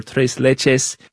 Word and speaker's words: tres leches: She felt that she could do the recipe for tres leches gelatina con mango.